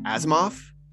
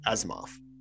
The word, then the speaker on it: Asimov: Isaac Asimov. – Hey, there you go. Weird. You said it right. – Asimov.